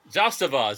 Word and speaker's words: Zastava: Zastava. – Oh. – Zastava. Good. Zastava is how you say it, actually.